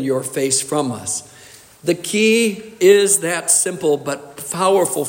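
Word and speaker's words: key (כי): This word, this little word, is pronounced key. That's right, as in a key which is put into a locked door and turned.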